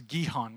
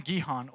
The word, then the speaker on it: Gihon: Hezekiah looked at the spring of, I think it's Gihon, that's how you say it.